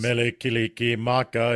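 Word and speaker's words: Mele Kalikimaka: You butchered it. – Butchered it. – Is that, that's not how it goes? – It's not how it goes. – Melikiliki Maka is the thing to say on a bright Hawaiian Christmas day.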